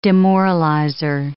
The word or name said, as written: demoralizer